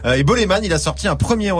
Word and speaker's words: Bolémvn: Boleman, hein, pour la petite précision, ça s'écrit avec un V à la place euh, du A, mais ça se prononce Boleman.